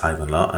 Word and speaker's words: Hayvanlar: H-A-Y-V-A-N L-A-R, that's Haivanla, and then Alemi, A-L-E-M-I, from Ankara in Turkey.